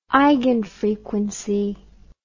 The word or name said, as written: eigenfrequency